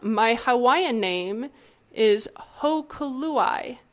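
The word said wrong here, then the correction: Hōkūlani: Hokulani. It's H-O with a line over it, K-U with a line over it, Lani, L-A-N-I.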